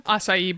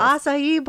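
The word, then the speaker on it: açaí: Not acacia bowls. What are they called? – Acai bowls. – Acai bowls. Yes.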